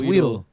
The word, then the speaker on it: Wheatle: It's not spelled like the Pokemon, but it's Wheatle. – Oh, yeah. Damn, son. – People would pronounce it Wheatley, though.